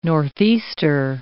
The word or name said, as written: northeaster